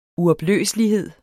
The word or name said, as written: uopløselighed